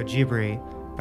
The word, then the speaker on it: Ghibli: Um, although someone just told me, who's a huge fan, told me that the proper pronunciation, even in the Anglicization, is Studio Ghibli.